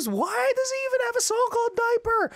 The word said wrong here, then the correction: Deeper: Or am I pronouncing it? Wait. I think it's pronounced deeper, not diaper.